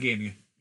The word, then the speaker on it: Gainey: It might be Gainey. Bob Gainey.